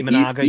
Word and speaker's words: Imanaga: And I would look at Imanaga, and I'm not sure if I'm pronouncing it correctly. – Yeah, Imanaga, yep.